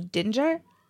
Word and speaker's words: Dinger: saying that it's not actually pronounced that way, that it's supposed to be a soft G, that it's supposed to be Dinger, like to rhyme with ginger, but that seems even weirder.